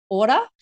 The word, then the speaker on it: Ora: So it's Ora Sleep Coaching, or if you pronounce it the Māori way, it's Ora, O-R-A, Sleep Coaching.